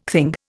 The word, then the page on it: thing: Indian